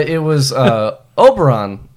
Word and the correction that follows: Oberlin: Ohio. Yeah. Overton. I don't know how to say it, but I went to Oberlin. – Oberlin.